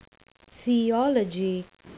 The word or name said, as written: theology